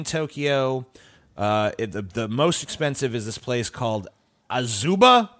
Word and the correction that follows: Azabu: Excuse me. It's Azabu.